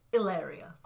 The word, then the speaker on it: Hilaria: So again, that spelling is H-I-L-A-R-I-A. So the H is silent. Hilaria. – Oh, no, so that's Hilaria. – I'm saying it this the way she says it.